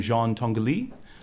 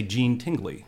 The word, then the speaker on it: Jean Tinguely: It's built by, and you can pronounce this two different ways, this Swiss architect named Jean Tinguely, or in Indiana we would say Gene Tingley.